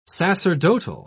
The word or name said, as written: sacerdotal